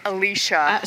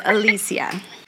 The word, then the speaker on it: Alicia: And Clint was like, Alicia. – So he's just reading it. – It's like, you mispronounced it. He's mispronouncing it, and Linda Gray's getting it right with Alicia.